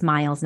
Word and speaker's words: Meils: It's tricky. Okay. Listen, it's M-E-I. It's spelled M-E-I. It's no fault at anybody's. But I always have to say, a funny thing that my husband say on our family photos is hashtag it's Meils, not Meals.